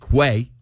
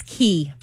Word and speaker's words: Quay: They look like Quay. Q-U-A-Y. – That's what I'd pronounce. – It's a nice brand. – And Kelly says it's Quay. – It's Key. – Key. – Key, like K-E-Y.